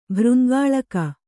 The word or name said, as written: ಭೃಂಗಾಳಕ